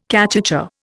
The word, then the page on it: cachucha: Indian